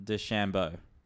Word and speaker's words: DeChambeau: Bryson DeChambeau. Did I say that right? – That's how the Americans say it.